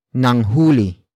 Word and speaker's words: nanghuli: For example, nanghuli ng isda si Cherry Ann. Nanghuli ng isda si Cherry Ann. Nanghuli ng isda si Cherry Ann. It means Cherry Ann caught a fish. Word for word, it's nanghuli.